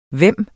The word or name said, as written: hvem